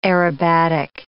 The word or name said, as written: aerobatic